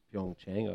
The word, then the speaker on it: Pyeongchang: Thanks for that pronunciation of Pyeongchang.